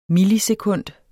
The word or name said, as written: millisekund